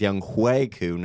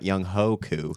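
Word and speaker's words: Younghoe Koo: It is Young Hweku, not Young Hoku.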